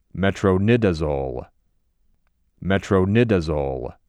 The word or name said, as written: Metronidazole